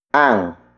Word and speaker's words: ang: Ang. Ang. Ang is spelled as A-N-G.